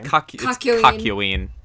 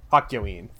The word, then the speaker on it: Kakyoin: Kakyoin. – Kakyoin, thank you.